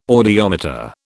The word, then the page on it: audiometer: British English